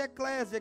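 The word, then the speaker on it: eclésia (ἐκκλησία): Se o significado igreja se pronuncia eclésia, que significa reunião, chamados para fora.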